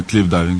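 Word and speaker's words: cliff diving: Еми и да, клиф дайвинг или хай дайвинг, в зависимост произношението, но обикновено е клиф дайвинг, защото използваме скали и естествени места, за които да скачаме също.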